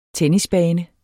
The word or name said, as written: tennisbane